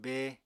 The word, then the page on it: bée: apatani